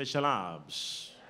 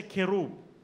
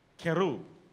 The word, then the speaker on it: Kerub: Say, Shalabs. Are you there? In the Hebrew pronunciation, they will say Kerub. Kerub. In the Greek pronunciation, Shalab.